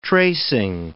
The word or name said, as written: tracing